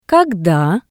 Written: kogda

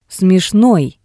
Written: smeshnoi

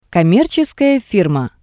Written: commercheskaya firma

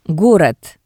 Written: Gorod

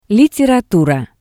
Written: literatura

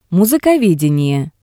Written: musikovedenie

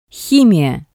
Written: himiya

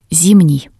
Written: zimnii

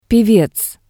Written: pevez

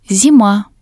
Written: zima

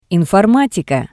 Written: informatika

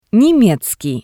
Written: nemezkii

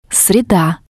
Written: sreda

